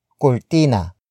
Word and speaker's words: kurtina: Cortina. Cortina. Cortina is spelled as K-U-R-T-I-N-A.